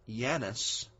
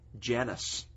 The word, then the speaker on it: Janus: It is because there is a Roman god, a Roman deity, by the name of Janus, or with the English J sound, Janus. Y-A-N-U-S, or I-A-N-U-S. We pronounce it Janus with the J.